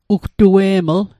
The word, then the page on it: ugtue'ml: Mi'gmaq